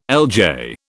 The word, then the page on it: LJ: British English